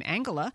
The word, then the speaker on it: Angela: I'll just tell you that Angela, as we learned from Carly Fiorina, she pronounces her name Angela.